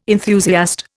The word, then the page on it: enthusiast: Indian